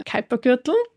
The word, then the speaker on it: Kuipergürtel: Und im äußeren Sonnensystem haben wir jetzt eben hinter der Umlaufbahn von Neptun auch ähm, also jede Menge Asteroiden gefunden im Kuipergürtel. Ja, endlich, endlich merke ich mir die Aussprache Kuipergürtel, so wie Hyper, Hyper, bei Scooter.